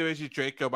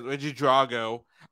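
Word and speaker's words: Regidrago: There was also whenever it started the elite raids, there was a real graphical glitch with Reggie, oh my god, I want to say Reggie Draco, but I know that's it, Reggie Drago, because I realized I was saying its name wrong. I thought it was a C, not a G, for a long time.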